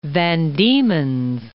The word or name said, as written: Van Diemen's Land